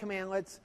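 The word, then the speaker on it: cmdlet: So CMD, LET, we can pronounce that commandlets.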